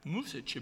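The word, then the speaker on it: Mušić: His First Lady was, was uh, actually Sonia Musich, but it's, it's spelled like music. Music. – Oh, okay. Musich.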